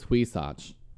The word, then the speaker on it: Huisache: It's Huisache. H-U-I only makes one sound. And S-A-C-H-E only makes one sound.